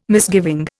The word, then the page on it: misgiving: Indian